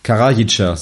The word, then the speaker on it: Karajica: So heißt das ja auch von Zeljko Karajica. Ich hoffe, ich habe das richtig ausgesprochen. Karajica, so heißt das richtig.